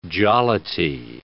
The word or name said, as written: jollity